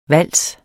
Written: vals

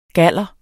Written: GALLEREN

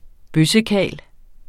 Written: bøssekarl

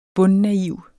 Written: bundnaiv

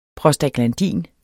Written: prostaglandin

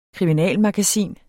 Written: kriminalmagasin

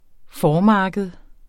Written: formarked

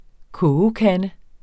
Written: kogekande

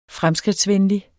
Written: fremskridtsvenlig

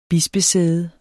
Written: bispesæde

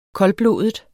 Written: koldblodet

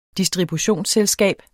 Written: distributionsselskab

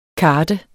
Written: karte

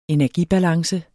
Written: energibalance

